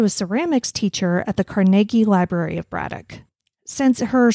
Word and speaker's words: Carnegie: Everybody outside of Pittsburgh pronounces it Carnegie. – I know, because it's the Carnegie Deli. – It's Carnegie.